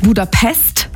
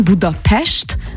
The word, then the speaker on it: Budapest: Also erstens appreciaten wir das schon mal, wenn man nicht Budapest sagt, sondern Budapest, weil das S immer ausgesprochen wird wie ein Sch.